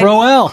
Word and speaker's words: Crowell: Well, changing gears, New York Jets running back Isaiah Crowell ran... Did I say it right? – Crowell. The crow's going to fly.